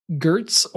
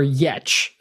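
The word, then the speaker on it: Giertz: And Simone Gertz, or Yetch, if you actually pronounce it correctly in her native language. – Have you actually verified this with her on the pronunciation of her name? – When she started the Yetch store, she said, "My last name is pronounced Yetch, hence that's the name of the store."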